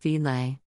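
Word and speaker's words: filé: That's pronounced filet.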